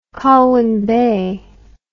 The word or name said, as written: colwyn bay